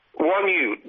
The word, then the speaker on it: Wonyu: It's phonetic. – Wonyu. WONYU.